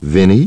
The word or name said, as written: Vinnie